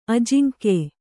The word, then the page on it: ಅಜಿಂಕ್ಯ: ajiŋkya